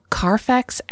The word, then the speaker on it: Carfax: Seeing the police wander through Carfax Abbey in complete bewilderment was truly compelling. Is that supposed to be said somehow Carfax? – No, you said it right.